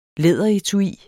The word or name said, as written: læderetui